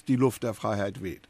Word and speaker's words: Die Luft der Freiheit weht: The German is, of course, the one that entered Stanford's seal and is Die Luft der Freiheit weht.